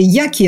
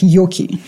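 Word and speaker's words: Yockey: Ну, скорее, Яки это по-американски произносится, но в российском интернете он как Йоки читается.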